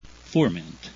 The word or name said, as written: furmint